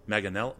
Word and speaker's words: Manganiello: Manganiello.